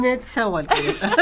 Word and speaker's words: Nezahualcóyotl: En Nezahualcóyotl. ¿Lo dije bien? – Netzahualcóyotl.